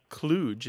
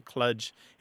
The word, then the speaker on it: kludge: And then the other word, just to dispense with that pretty quick, kludge is how it's often said, though kludge is how it looks like it's spelled, K-L-U-D-G-E,